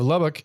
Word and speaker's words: Lubbock: Am I saying it right? Lubbock. Lubbock. – No, I think the second time you went to French. – Lubbock.